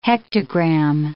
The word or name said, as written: hectogram